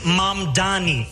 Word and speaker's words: Mamdani: My name is Assemblymember Zahran Mamdani and I am running to make the city affordable. The name is Mamdani, M-A-M-D-A-N-I. You should learn how to say it because we got to get it right.